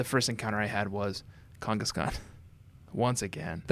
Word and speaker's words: Kangaskhan: Am I saying it right now, Josh? You want me to elongate the A's? – Yeah, you got it. Kangaskhan. – Kangaskhan.